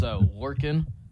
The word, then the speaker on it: Lorcan: It is Lorcan. So L-O-R-C-A-N and then Quinn. Lorcan Quinn.